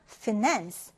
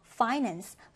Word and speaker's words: finance: 자, 이 단어는요, 강세와 발음이 이제 두 가지가 가능합니다. 일단 finance, finance 이렇게 발음해도 되고요. 또는 이제 첫 음절에 강세가 오기도 해요. 그래서 finance, finance로 발음하기도 하는데 두 가지 다 가능합니다.